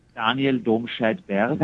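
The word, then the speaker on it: Domscheit-Berg: Daniel Domscheitberg. – That's how name should be said. Why can't we do that? Why can't we say names like that?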